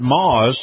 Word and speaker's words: Maus: And this portion of the program brought to you by our friends at Moss, Moss Acura. Moss is spelled M-A-U-S.